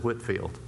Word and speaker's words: Whitefield: It's actually spelled, it's spelled like Whitefield, but you pronounce it Whitfield.